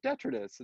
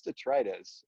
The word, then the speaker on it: detritus: Like, that's not how you pronounce it, detritus. And they're like, no, it's detritus. And I look it up, and they were right.